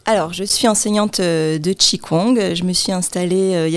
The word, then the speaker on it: Qigong: Alors, je vous présente, vous êtes enseignante de Qigong.